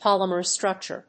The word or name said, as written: polymer structure